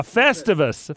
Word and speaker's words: Festivus: Festivus. Festivus for the rest of us. – Oh, leaving a syllable off.